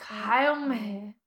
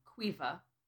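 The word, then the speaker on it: Caoimhe: Okay, it's pronounced Quiva. – Quifa? – Quiva. – Oh, okay, not Quifa.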